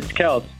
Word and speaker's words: Kelce: I guess I've been pronouncing it wrong the whole time, too. I even checked on Chiefs Twitter. Chiefs Twitter also says we've been saying it wrong. It's Kels.